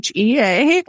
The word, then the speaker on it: Shea: You can find me on Instagram at my full name, which is Heather Alice Shea. My last name is spelled like the butter Shea. So Shea.